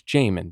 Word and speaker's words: Jamin: Because after day one, even though I didn't know how to say his fucking name, I was calling him Jamin Davis, Jamin Davis. It's Jamin Davis.